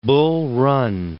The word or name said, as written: Bull Run